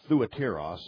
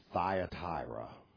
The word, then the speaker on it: Thyatira: If you take it in the Greek, if you look at it in the Greek, you's always become Y's when they're transliterated in English. So it's Thuatiros, literally, or we get our English pronunciation, Thyatira.